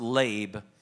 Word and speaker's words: lev: And it's translated heart, and it's lab, L-E-V, pronounced lab.